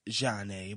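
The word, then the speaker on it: Zhané: And their album was called It's Pronounced Jeanne, but it's spelled Z-H-A-N-E. And the song I'm going to play for you is called Hey Mr. DJ. And it's by Jeanne.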